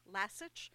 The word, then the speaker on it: Lasich: My name is spelled L-A-S-I-C-H, Lasich, and she would say, just think like the dog Lassie, and Lassie itches, Lasich.